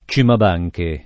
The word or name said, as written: Cimabanche